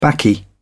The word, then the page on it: baccy: British English